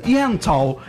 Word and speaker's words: Yentl: Ó, a querida Alessandra Maestrini, ela tá em peça, ela tem peça, que é a Ente. Ente in Concert. – Yentl. – Eu vou fazer com pronúncia Yentl in Concert.